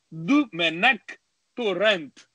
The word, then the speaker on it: Domènec Torrent: Então é Domènec Torrent. E o T, os catalães gostam de fazer o T meio T né? meio.